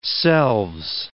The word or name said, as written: selves